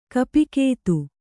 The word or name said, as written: ಕೋಪಗಾತಿ